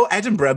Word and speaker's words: Edinburgh: Did I say it wrong? – No, Edinburgh, but that's where I went to uni.